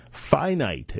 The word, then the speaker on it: Phinite: Finite, and this is spelled P-H-I-N-I-T-E.